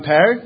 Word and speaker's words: Per: So first, I'm Per. If you pronounce my name like the fruit pear, you're spot on.